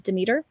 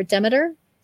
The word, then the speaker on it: Demeter: The story, it's a pretty common Greek myth with Persephone and Demeter, or Demeter. There, people pronounce her name differently.